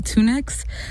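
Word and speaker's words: TUNEXX: And then you listen to Blue Mode by Tunex, and I think I'm saying it right, Tunex. That's T-U-N-E-X-X off of their second mini album, Blue Mode.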